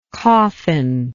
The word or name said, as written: coffinless